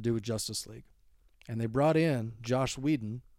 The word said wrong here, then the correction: Joss Whedon: Or, I'm sorry, I got to pronounce that. Make sure I really enunciate that. Joss Whedon.